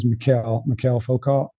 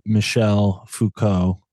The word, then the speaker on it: Michel Foucault: And so I thought his name was Mikhail Foucault. How do you say it? – Well, he's French, so I would say Michel Foucault.